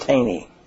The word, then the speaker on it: Thoene: T-H-O-E-N-E. – It's pronounced Taney.